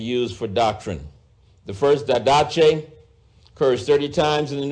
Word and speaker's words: didache: Didache. D-I-D-A-C-H-E. Or you might look at it as didache, but it is pronounced didache, and it is translated doctrine in the King James Version.